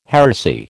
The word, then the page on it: heresy: American English